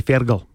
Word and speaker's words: Fergal: How do you say it? – Fergal. Or Fergal. – Isn't it Fergal? – I say Fergal. That's how the Irish say it.